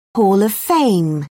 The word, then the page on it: hall of fame: British English